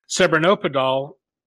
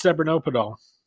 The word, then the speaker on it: Cebranopadol: The transaction gave you the experimental therapy, Cebranopadol. What is Cebranopadol? – So, by the way, congratulations. You pronounced first time without any difficulty. I tell you, not easy.